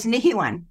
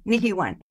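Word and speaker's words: Nihewan: This would be a perfect time to talk about your beautiful foundation, Nahiwan. Am I saying that right, Buffy? – We are pretty close. Nahiwan. Nahiwan.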